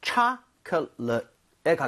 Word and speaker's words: chocolate: chocolate 할 때는 앞에 오의 강세가 가죠. 그러면서 입 모양이 좀 벌려지겠죠. 그래서 초콜릿보다는 chocolate에 가깝고요.